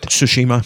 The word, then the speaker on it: Tsushima: I've been playing Ghost of Tsushima. Is that how you pronounce it? – Tsushima. – Tsushimi. – Yeah.